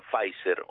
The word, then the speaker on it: Pfizer: escríbese Pfizer, pero eu creo que a pronunciación Pfizer ou así os americanos,